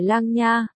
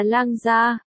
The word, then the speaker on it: Lang Gia: Cô gái cười lắc đầu, ánh mắt tinh nghịch, không phải Lang Nha. Là Lang Gia, đồng âm khác nghĩa, Lang Gia của Lang Gia Thái. Những mục dân này vốn tiếng Hán không tốt, cho nên phát âm sai từ này, đều cười hiền lành.